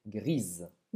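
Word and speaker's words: grise: Gris, você não vai pronunciar o S e grisa. Você vai pronunciar um Z. Grisa.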